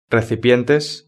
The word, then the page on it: recipientes: Spain Spanish